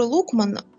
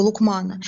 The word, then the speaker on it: Лукман: И вот пророк Дауд, Давид, он же назначил его судьей этого Лукмана. И известно наставление Лукмана своему сыну. Правильно говорит Лукман, да, не Лукман.